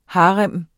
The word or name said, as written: HAREMMER